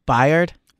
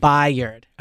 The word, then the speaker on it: Byard: Byard. – I believe so. – Okay. So he is... – Could be Bird. It's B-Y-A-R-D. – I think it's B-Yard. Like I thought it was Byard, but it could be B-Yard.